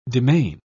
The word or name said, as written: demesne